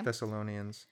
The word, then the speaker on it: Thessalonians: With this quote, but I don't know how to pronounce this word right here. Do you know what this is? It's biblical. Thessalonians. – I think you got it. – Okay. – Thessalonians.